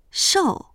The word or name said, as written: shou4